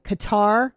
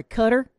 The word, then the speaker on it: Qatar: So Bahrain, Dubai, Qatar, I'm sorry, Qatar is how they say it there.